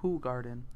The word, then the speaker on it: Hoegaarden: Actually, it's pronounced Hoegaarden.